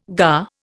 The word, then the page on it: gar: Indian